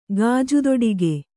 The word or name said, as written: ಗಾಜುದೊಡಿಗೆ